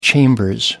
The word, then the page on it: chambers: British English